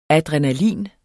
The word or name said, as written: ADRENALINET